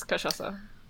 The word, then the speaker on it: cachaça: I thought it was cachaça. – No, it's cachaça.